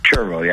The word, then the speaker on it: chervil: So the town is also asking residents to look for and report any patches of wild, is it Chervil? – Chervil, yeah. I've heard it pronounced a few ways, but I call it Chervil.